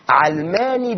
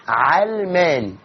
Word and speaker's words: علماني: الكلمة في الاصل هي وضعت في المعاجم مؤخرا، لكن هي في الاصل ما تنطقهاش بكسر العين، هي مش من العلم زي ما يخدعنا البعض ان علماني يعني عالمية او يعني علمية. علماني بفتح العين، لا لها علاقة بالعلم ولا بالعالمية، اسمها علماني، علماني بفتح العين.